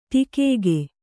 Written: ಠಕ್ಕಿಕ್ಕು

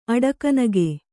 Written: ಅಡಕನಗೆ

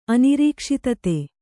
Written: ಅನಿರೀಕ್ಷಿತತೆ

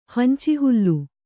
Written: ಹಂಚಿಹುಲ್ಲು

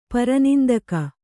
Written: ಪರನಿಂದಕ

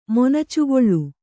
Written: ಮೊನಚುಗೊಳ್ಳು

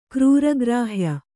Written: ಕ್ರೂರಗ್ರಾಹ್ಯ

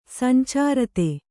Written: ಸಂಚಾರತೆ

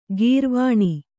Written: ಗೌರೀವೀಣೆ

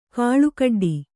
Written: ಕಾಳುಕಡ್ಡಿ

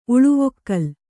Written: ಉಳುವೊಕ್ಕಲ್

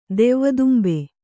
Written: ದೆವ್ವದುಂಬೆ

